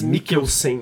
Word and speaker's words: Mikkelsen: É, é o Mads, Mads Mikkelsen. – Mikkelsen.